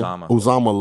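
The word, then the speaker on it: Uzomah: The Bengals are the one team in that division that didn't have any major defections, because even though CJ how do you say his last name? – Uzama left.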